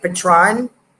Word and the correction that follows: Patreon: Is that correct? – Patron. Patreon.